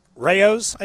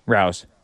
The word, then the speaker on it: Rao's: May I suggest Rao's, I believe is how it's pronounced. R-A-O.